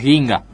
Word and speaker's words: Guinga: Quizás por el perfil bajo del tipo, que es Ginga. – Uh-huh. – Se escribe Guinga.